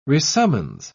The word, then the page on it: resummons: British English